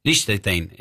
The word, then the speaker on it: Liechtenstein: Liechtenstein, ahí está, lo dije bien.